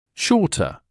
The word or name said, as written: shorter